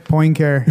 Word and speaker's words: Poincaré: Henri Poincaré? – Poincaré, yeah. Poincaré connection.